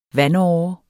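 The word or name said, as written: VANDÅREN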